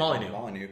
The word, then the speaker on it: Molyneux: Molyneux.